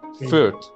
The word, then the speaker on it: Fürth: And Fürth.